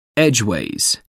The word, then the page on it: edgeways: American English